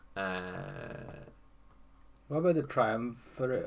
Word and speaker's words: triumvirate: Triumvirate. – Triumvirate. Let me just. – Triumvirate.